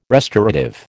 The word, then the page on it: restorative: American English